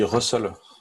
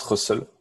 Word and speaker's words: rustls: On dit Russell. Ouais. Moi, je me suis arrêté là-dedans. Dans le papier, il y a marqué « Pronounced Russell ». Russell.